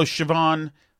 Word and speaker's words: Siobhan: It's spelled wildly wrong. S-I-O-B-H-A-N.